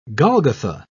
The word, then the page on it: golgotha: British English